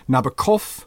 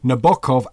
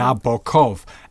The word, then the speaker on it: Nabokov: And he said, as to pronunciation, Frenchmen, of course, say Nabokov with the accent on the last syllable. Englishmen say Nabokov, accent on the first. And Italians say Nabokov, accent in the middle, as Russians also do, as you do, Rebecca. Mm-hmm. Nabokov, a heavy open O, as in Knickerbocker.